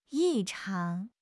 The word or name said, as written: 异常